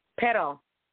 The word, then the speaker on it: pero: So let me see a word that has just one R. Pero. You can pronounce the R. Pero means but. P-E-R-O. But. B-U-T.